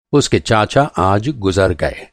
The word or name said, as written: गुज़र